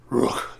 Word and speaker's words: Rukh: it's the way it's spelled is R-U-K-H. So you can't even say Rook, it's just Rook. You gotta have like a little bit of gargle behind you.